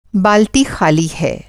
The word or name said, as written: बालटी